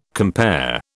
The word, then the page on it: compare: British English